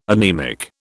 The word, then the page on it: anaemic: British English